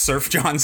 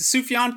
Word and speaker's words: Sufjan: Like, he in my head is always just Surf John Stevens. – I don't think which, I like, know how to pronounce it actually. – Yeah, you know? I think it's Sufjan, but it doesn't really matter.